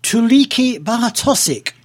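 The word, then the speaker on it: Tuulikki Bartosik: Here she's joined by a lady from Estonia by the name of, and I hope I get this pronunciation right, Tuliki Bartosik. – You did that rather well, I think.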